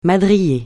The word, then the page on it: madrier: francais